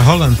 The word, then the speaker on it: Haaland: Y Haaland, pues también se pronuncia Holland también.